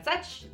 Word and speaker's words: Szuch: Check out the ridiculousness of my Instagram post at Robin Joanne Such. That's me. And the last name is S-Z-U-C-H. Crazy. Silent Z.